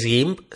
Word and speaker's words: GIMP: Y ahora vamos con la quinta opción que para mí es la favorita, mi favorita, que es GIMP, así según se, se, se escribe y se lee, G-I-M-P, y es una herramienta que se parece un montón a Photoshop, es gratuita, te, te, te permite hacer maravillas.